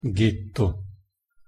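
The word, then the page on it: ghetto: Italian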